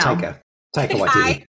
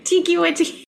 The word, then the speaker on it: Taika Waititi: Taika Waititi. – What did I say? – Tika. You said it like it was a tiki. Tiki Waititi. It's definitely Taika.